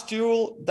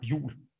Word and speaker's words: Juul: Lars Juul, that's absolutely fine. – All right. – That's probably how you would say it in English. In Danish, it's said like Juul.